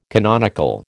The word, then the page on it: canonical: American English